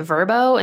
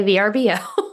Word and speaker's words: VRBO: which I have realized from their commercials that they pronounce it Verbo, and that just makes me weird.